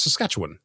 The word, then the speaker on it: Saskatchewan: I learned yesterday that Saskatchewan, as we pronounce it, is actually wrong. The way that they pronounce it there is Saskatchewan.